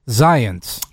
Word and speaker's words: Zients: Am I pronouncing that right? – Zients. – Zients. – As in science.